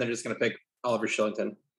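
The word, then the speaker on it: Kylington: Excellent pronunciation, by the way, of Shillington. He is it is a soft K. – Yeah, I'm an actor, so I know how to pronounce those soft K's. – But yeah, he's Swedish, and I never know in Swedish if I'm supposed to say K or Sh.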